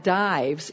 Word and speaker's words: Dives: Okay, well, that Dives is not the man's name. That's actually a Latin word and it's pronounced Dives.